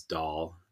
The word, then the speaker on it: Dhall: I think I would really love to explore one of these build tools that uses DALL. The DALL language is, how do you spell that? – DHALL. It's like the Indian dish.